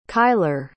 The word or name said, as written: kylar